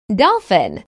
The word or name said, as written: dolphin